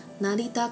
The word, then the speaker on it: 成田: 那成田的发音是 narita，narita。那机场的汉字呢，写作空港。天空的港口就是日文里的机场。空港念作 kuko，kuko。那成田空港念作 narita kuko。